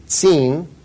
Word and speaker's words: Tsin: The wilderness here in this location, it's the name of the wilderness is Zin, which is the Hebrew Tsin. It's pronounced like T-S-E-E-N.